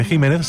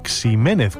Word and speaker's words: Jiménez: Así, por ejemplo, la variedad de una uva conocida como Pedro Jiménez se pronuncia así, aunque ese Jiménez se siga escribiendo con X, si bien también se escucha mucho decir Jiménez e incluso Ximénez cuando se pierde ya la referencia de su pronunciación original.